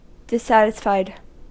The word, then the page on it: dissatisfied: American English